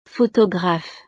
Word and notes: photographe: frances

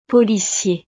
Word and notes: policier: frances

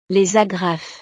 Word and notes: les agrafes: frances

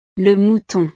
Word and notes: le mouton: frances

